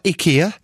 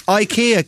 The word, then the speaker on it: IKEA: That's not how you say it. – No. – It's not IKEA. No one says that. What are you on about? IKEA. Shut up. It's IKEA, clearly. It's been that for years.